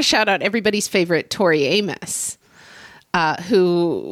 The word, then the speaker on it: Amos: So everybody's favorite Tori Amos, which rhymes with virus.